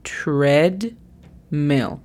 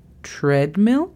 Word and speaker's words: treadmill: La pronunciación fonética es T-U-R-E-D-M-I-L. Treadmill. Si la U después de la T te parece algo complicada, y la haces muy larga y se escucha ture en lugar de treadmill, es porque la estás haciendo muy larga, o simplemente quita la U y di TR, como más se te facilite.